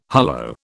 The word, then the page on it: hullo: British English